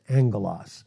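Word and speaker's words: angelos (ἄγγελος): It's angelos, A-G-G-E-L-O-S. First G is silent, and it's pronounced angelos.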